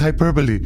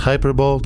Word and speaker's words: hyperbole: It is hyperbole. – I believe it's pronounced hyperbole, Dr. Smoggy.